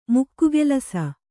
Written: ಮುಕ್ಕುಗೆಲಸ

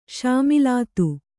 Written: ಶಾಮಿಲಾತು

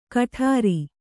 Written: ಕಠಾರಿ

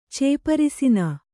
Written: ಚೇಪರಿಸಿನ

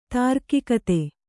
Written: ತಾರ್ಕಿಕತೆ

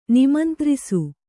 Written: ನಿಮಂತ್ರಿಸು

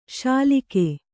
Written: ಶ್ಲಾಘಿ

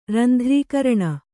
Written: ರಂಧ್ರೀಕರಣ

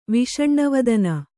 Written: ವಿಷಣ್ಣವದನ

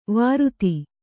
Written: ವಾರುತಿ

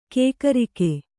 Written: ಕಕ್ಕರಿಕೆ

